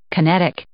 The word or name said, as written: kinetic